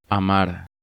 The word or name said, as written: Amar